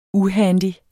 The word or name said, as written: uhandy